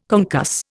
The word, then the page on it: concuss: Indian